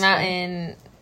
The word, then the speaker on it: mountain: It's just mountain. – Yeah, it's like most people from California don't pronounce the T, and I was like, it's funny.